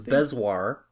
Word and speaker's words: bezoar: We got about our bringing up of Bezor. Am I saying it right now? Bezor. – Well, we got different feedback. It's hard to say. The different first feedback we got was Bezoar.